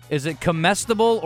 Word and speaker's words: comestible: Is it comestible or comestible? – Comestible. – Comestible.